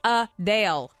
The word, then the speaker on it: Adele: Number one, Adele says, we've been pronouncing her name wrong this whole time. It's Adele, not Adele.